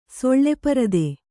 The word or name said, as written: ಸೊಳ್ಳೆಪರದೆ